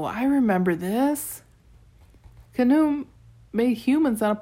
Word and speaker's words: Khnum: That'd be pronounced Khnum, I suppose. Rhymes with kaboom?